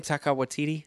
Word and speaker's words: Taika Waititi: Taika Waititi? – Yeah.